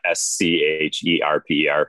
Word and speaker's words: Scherper: So my last name is spelled uh, S C H E R P E R Scherper, and the mm-hmm.